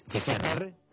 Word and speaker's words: Finisterre: Finisterre.